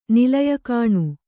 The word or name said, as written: ನಿಲಯಕಾಣು